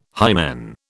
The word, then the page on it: hymen: British English